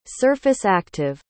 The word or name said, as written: surface-active